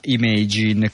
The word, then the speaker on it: Imagine: una onlus no profit che si chiama Imagine, credo che si pronuncia all'inglese visto che è scritto Imagine, quindi la pronunciamo all'inglese.